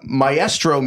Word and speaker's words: maestro: He's like, maestro means teacher in Spanish, and that is the way it is pronounced in Spanish.